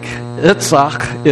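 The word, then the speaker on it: Itzhak: Yitzhak is the Hebrew pronunciation of his name.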